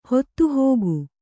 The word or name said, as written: ಹೊತ್ತುಹೋಗು